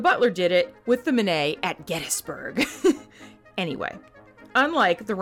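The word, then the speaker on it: Minié: Most Americans pronounce this round as mini, like the mouse. But the guy who invented it was French, so Manet is probably more correct.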